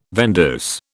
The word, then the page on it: vendeuse: British English